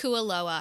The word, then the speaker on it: Kualoa: It's Kualoa.